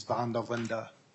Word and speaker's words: van der Linde: It's pronounced like mandolin, but with a V. – Vandolin. – So, the actual Dutch pronunciation is van der Linde.